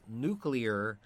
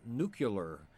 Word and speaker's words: nuclear: Now, the impact of a nuclear bomb, oh, by the way, it is pronounced nuclear, not nuclear, like President Bush and always called it a nuclear bomb.